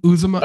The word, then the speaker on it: Uzomah: Uzomah, Uzomah, oh yeah, the tight Uzomah.